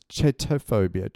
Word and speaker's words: chaetophobia: No, and it's chaetophobia, chaetophobia. – But it's spelled chait.